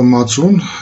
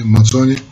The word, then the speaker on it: мацун: А Само слово «мацун» – это такое армянское произношение этого слова. Вы, наверное, слышали и грузинский вариант его произношения – «мацони».